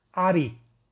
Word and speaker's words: Ari: Y deberás mencionar el santo nombre de Hashem Al-Ain Reish Yud, que se pronuncia Ari, y que es el nombre de Dios número 46 de los 72 nombres, y también es usado y apropiado para adquirir confianza.